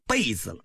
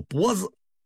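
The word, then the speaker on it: 勃: 不过有时候这种敬畏也来自另一方面，比如说有一次在这个经验上，万历帝读这个《论语》，把这个色波如野的这个脖子误读成啊被子了，张居正厉声说当做脖子，他的声音是那样的响啊，把这个皇上吓了一跳。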